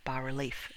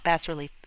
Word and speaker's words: bas-relief: So see, I, I looked up how to pronounce bas-relief. It's not bas-relief, it's bas-relief.